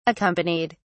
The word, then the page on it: accompanied: British English